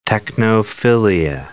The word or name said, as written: technophilia